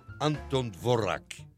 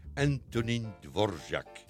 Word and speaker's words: Antonín Dvořák: In the 19th century was born in Czechy a great composer, Anton Dvorak. But I prefer to pronounce it in Czech, Antonín Dvořák.